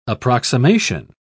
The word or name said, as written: approximation